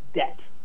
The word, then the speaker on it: debt: Other things like debt, D-E-B-T, a timely word, as we all might know. Why is that B in there when we don't pronounce it?